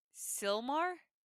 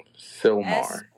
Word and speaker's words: Sylmar: We're gonna watch Law and Order, LA, Silmar? Season 1, episode 4. – Silmar. – S-Y-L-M-A-R. Silmar? Is that how you pronounce it?